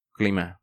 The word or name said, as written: Clima